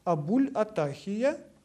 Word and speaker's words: Абуль-Атахия: Это Абуль-Атахия, пишется Абу, дефис, Л мягкий знак, дефис, Атахия. Абуль-Атахия.